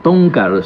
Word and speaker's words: Town Car: Bueno, vamos a hablar un poco ahora del auto, del Lincoln, el Town Car, ¿así es como se pronuncia? ¿Estamos pronunciando bien? – Town Car, sí.